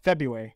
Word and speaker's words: February: There's a hidden There's a hidden R in the middle, though. – February.